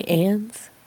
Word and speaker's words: Auntie Anne's: Say Anne's. It's not pronounced Annie's. Like, there's no I.